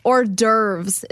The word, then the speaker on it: hors d'oeuvres: Hors d'oeuvres is how you pronounce the other one.